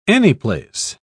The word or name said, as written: anyplace